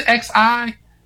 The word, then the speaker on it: Xi: Well, I can get the Lambda Mu Omega chapter, but please help me on the what's the XI? – The Xi. – Oh, it's the Xi chapter.